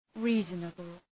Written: reasonable

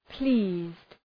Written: pleased

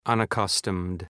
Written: unaccustomed

